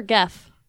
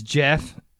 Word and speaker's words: Gef: Everybody says Jeff, but it's actually spelled G-E-F. – G-F, but it's pronounced with the same G that you would use in like get. So it's pronounced Geoff.